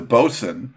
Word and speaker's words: boatswain: It's a bosun double IPA. It's spelled boatswain, but that's like a deck boss on a boat. But you just call it a bosun, and the beer looks really good.